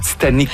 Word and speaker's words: Titanique: C'est important de préciser que vous dites Titanic parce que ça s'écrit T-I-T-A-N-I-Q-U-E.